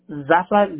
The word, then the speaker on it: Zafra: Zafra, Z-A-F-R-A. – Can you spell that again? Z-A... – Z-A-F-R-A.